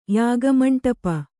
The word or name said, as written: ಯಾಗಮಂಟಪ